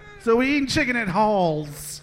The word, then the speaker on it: Halls: Halls. It's called Halls. – Halls. – Spell that. – H-A-L-L-S. – Halls. Halls. Like the medicine candy.